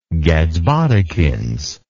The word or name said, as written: gadsbodikins